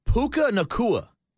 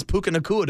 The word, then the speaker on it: Puka Nacua: Well, he probably didn't know how to pronounce Puka Nacua, to be honest with you. Sounds like Hakuna Matata.